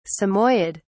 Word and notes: samoyed: British English

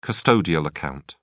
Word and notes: custodial account: British English

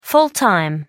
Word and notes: full-time: American English

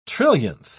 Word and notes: trillionth: British English

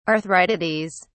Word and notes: arthritides: British English

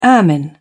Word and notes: ermine: British English

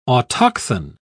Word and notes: autochthon: British English

